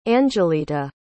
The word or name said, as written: Anjelita